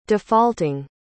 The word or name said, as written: defaulting